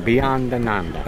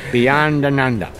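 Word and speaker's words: Beyondananda: It's an Italian name, Beyond Ananda. That's how it is. You've got to use your fingers when you talk that name. Beyond Ananda.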